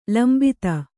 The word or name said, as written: ಲಂಬತೆ